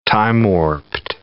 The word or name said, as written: time-warped